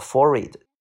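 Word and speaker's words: forehead: OK 啊，下个单词叫做 forward。记住这个单词的 h 不发音，forward，for 就是呃前边的，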